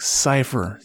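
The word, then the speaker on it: Cyphre: Because at first he's like, how do you pronounce this? Cipher? And he's like, Cipher.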